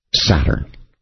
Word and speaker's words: Satur: Saturn. Now the name Saturn in Chaldee is pronounced Satur, but as every Chaldee scholar knows, consists only of four letters, S-T-U-R, almost like star, isn't it?